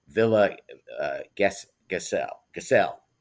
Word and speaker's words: Villa Gesell: I mean, the Geseldome, the author Sacamano lived in Villa Gesell, accent on the second syllable.